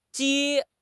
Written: ji4

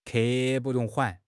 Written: 刻不容缓

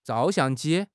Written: zhao4 xiang4 ji1

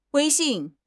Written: 威信

